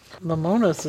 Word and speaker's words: Lemonis: We noticed that Marcus Lemos, how do you pronounce his name? – Lemonis is how I would say it. Looks like a Greek name.